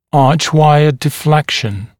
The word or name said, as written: archwire deflection